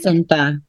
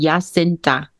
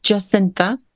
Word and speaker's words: Jacintha: So in Dutch, it would be Jacintha. – Yes, oh, beautiful. – But okay. Yes, because I live here now, and that seems to be impossible to pronounce for everyone. Now it's just Jacintha.